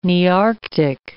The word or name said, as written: Nearctic